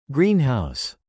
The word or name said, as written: greenhouse